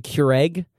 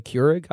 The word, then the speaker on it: Keurig: My aunt mispronounces them. She calls it the Keurig. It's called a Keurig. I always have to correct her on that.